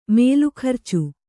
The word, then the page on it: ಮೇಲುಖರ್ಚು: kannada